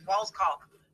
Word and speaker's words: Wallskog: And we were butchering his name as we were trans. It's Walskog, W-A-L-L-S-K-O-G.